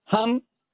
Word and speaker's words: Ham: Cuando su merced inhale, vamos a pronunciar la mitad del mantra, que es H-A-M, o sea, Ham.